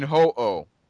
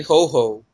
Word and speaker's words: Ho-Oh: How did you pronounce their names? – Lugia, I say Lugia and Ho-Oh. – I say Ho-Ho. – Ho-Ho? – Yes. – that ain't motherfucking Santa Claus.